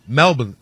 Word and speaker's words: Melbourne: We saw them put B to A against the Los Angeles Rams over in Melbourne Melbourne, apparently it's pronounced, in Australia.